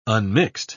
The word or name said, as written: unmixed